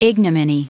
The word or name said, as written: ignominy